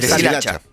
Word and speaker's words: deshilacha: Woody se deshichala. – Deshilacha. – Deshilacha, lo dije mal.